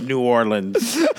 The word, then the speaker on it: New Orleans: Nolens. – Don't fuck it up. Nobody says Nolens here either. It's one of the sticking points. It's New Orleans.